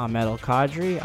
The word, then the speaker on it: Ahmed Alkadri: Again, my name is Ahmed Al-Qadri, and if you can't pronounce it, Ahmed Al-Qadri.